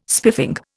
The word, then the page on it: spiffing: Indian